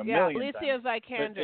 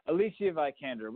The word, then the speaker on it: Vikander: Alicia Vikander. – Yes, Alicia Vikander.